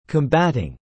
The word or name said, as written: combatting